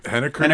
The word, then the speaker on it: Henniker: Am I saying that right? – Henniker, New Hampshire.